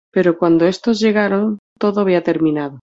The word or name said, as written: terminado